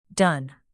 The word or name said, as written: done